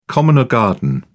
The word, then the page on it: common or garden: British English